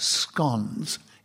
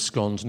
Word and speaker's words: scones: Can you tell us something about scones in just a minute, starting now? – Well, I'm glad that you pronounced it scones, Nicholas, because, as you know, a lot of people seem to think it's scones,